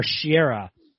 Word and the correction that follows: Teixeira: It's Teixeira. Yeah. – Yes. Teixeira.